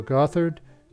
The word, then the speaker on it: Gothard: Um, when I was on a previous episode, I mentioned I, it was called Bill Goddard. Well, I mispronounced his name. It's actually Bill Gothard, G O T H A R D.